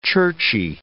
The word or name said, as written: churchy